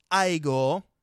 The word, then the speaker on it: Igor: And he's like, "It's pronounced Igor."